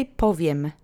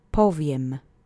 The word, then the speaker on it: powiem: Let's say powiem together three times. The accent is on the first syllable. Powiem. Powiem.